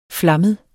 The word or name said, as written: flammet